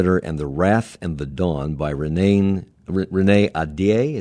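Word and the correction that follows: Ahdieh: Is that how you say her name? – Adia. – Mm-hmm. Adia.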